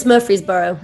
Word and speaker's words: Murfreesboro: It's Murfreesboro in Tennessee.